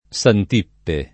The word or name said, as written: Santippe